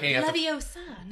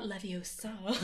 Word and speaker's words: Leviosa: Like, Leviosa, not Leviosa.